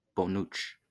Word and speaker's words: Bonucci: Now, how do you pronounce this fellow's name? – His first name is the last name, yeah. – Bonucci. – Ah, excellent. So, we have the Bonucci, which you have to hold your fingers and do.